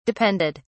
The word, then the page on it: depended: British English